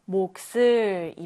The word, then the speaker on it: 몫을: 이럴 때에는 이 이응을 밀어내고 시옷이 올라가서 목슬 이렇게 읽는데요.